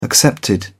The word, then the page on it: accepted: British English